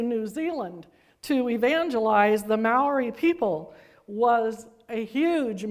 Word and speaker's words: Maori: These are the indigenous people of New Zealand, M A O R I, and most of the people I heard pronounce it Ma- Maori, the R sounds like a D. I can't quite uh, pull that one off.